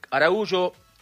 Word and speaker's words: Araújo: Bueno, final de primer tiempo entonces, en Miami, Uruguay 2, Araújo, porque me quedó con la pronunciación portuguesa, que es el origen de ese apellido, y Canovio, los goles para la selección oriental.